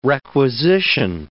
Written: requisition

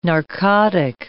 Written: narcotic

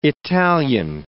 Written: Italian

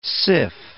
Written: syph

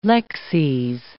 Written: lexes

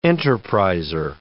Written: enterpriser

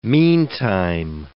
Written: meantime

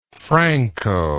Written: francoist

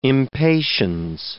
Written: impatiens

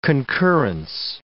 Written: concurrence

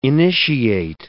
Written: initiate

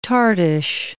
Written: tartish

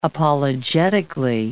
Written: apologetically

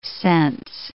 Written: cense

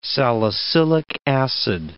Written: salicylic acid

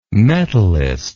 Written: metallist

